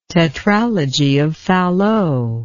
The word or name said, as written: tetralogy of Fallot